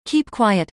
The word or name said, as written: Keep quiet